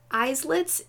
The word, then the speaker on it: islet: or the appearance of new islets? How do you say that word? – I-S-L. – Small islands. – E-T. Islet? – Of new small islands. – Is it maybe an islet? Islet, maybe.